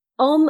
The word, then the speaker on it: Om: Om, as in from, with a short O, as in the first O in Oxford.